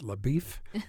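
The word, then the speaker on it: LaBoeuf: But they all pronounce it LaBeef.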